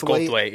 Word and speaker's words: Goldthwait: Is that how you say it? – Goldthwait? Yes, Goldthwait, yep.